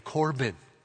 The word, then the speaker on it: Corban: And it was called the Law of Korban, C-O-R-B-A-N, Korban.